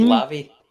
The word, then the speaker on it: Lave: You need to go on Lave Radio. Is it Lave Radio? Yeah, you need to go on that and ask him there. – I think you find it's pronounced Lavi.